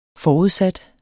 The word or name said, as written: forudsat